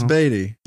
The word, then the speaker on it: Beatty: Ned Beatty, isn't it? – Beatty. I don't know. – It's Beatty. – Ned Beatty. – How'd they spell it? – B-E-A-T-T-Y. – Okay, yeah.